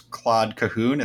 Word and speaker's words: Claude Cahun: is Claude Cahun.